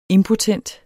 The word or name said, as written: IMPOTENTE